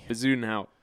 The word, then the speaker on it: Bezuidenhout: D-E-N. – Uh-huh. – H-O-U-T. – Oh, my. I mean, that's easy. – Bezuidenhout.